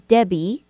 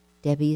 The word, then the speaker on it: Debby: My handle for Facebook, just go under my name, which is Debbie, D-E-B-B-Y. Debbie is spelled D-E-B-B-Y. And Handrich, my last name, is just like it sounds. H-A-N-D-R-I-C-H.